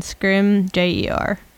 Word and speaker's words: Scrimgeour: Pronunciation, Scrimgeour. – Brutus Scrimgeour. – Scrim rhymes with trim. Ger rhymes with sir.